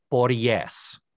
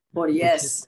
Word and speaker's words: Poryes: I'm going to say P-O-R-Y-E-S. He must be a Latine gentleman, is now the new politically correct term. And his last name is pronounced Porries. – Porries.